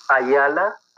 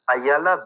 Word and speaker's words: Ayala: Yes, it's Ayala, Ayala dot zero. Yes, Ayala dot zero.